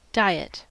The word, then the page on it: diet: American English